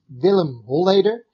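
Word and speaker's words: Willem Holleeder: Well, if you would pronounce it in Dutch, it would be Willem Holeder.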